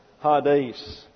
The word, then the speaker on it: Hades: And this is the Greek word, H-A-D-E-S, Hades. And this is a eta here, and it gets an A sound in the Greek, but when it comes into the English, we pronounce it like our E, and we get Hades.